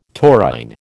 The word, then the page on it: taurine: American English